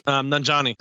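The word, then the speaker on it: Nanjiani: Is it Kumjini? – Nanjani. – Nanjani. Yeah, Camille.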